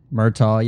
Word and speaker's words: Murtaugh: Am I saying that right? Is that Murtaugh the black one? – Murtaugh, yeah, I think I think that's Sounds right.